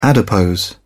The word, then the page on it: adipose: British English